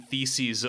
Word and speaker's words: theses: theses of the show. – Did you say feces? – Yes, I said theses, the plural of thesis, which does rhyme with doo-doo.